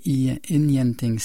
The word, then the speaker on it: Ingenting: Quand je vous disais que le site Forvo, c'était bien pratique pour s'assurer de la bonne prononciation, je viens d'aller voir Inyanting. J'avais prononcé Ingenting, mais c'est Inyanting.